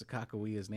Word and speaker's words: Sakakawea: We also know the correct way to pronounce Sakakawea's name.